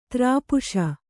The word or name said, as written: ದ್ರಪ್ಸ